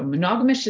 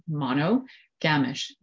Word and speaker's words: monogamish: So monogamish is spelled M-O-N-O, mono, gamish, G-A-M-I-S-H. So yes, monogamish, but monogamish, it's like ishish. You know, it's like monogamy with an ish. It's like reddish, brownish, yellowish.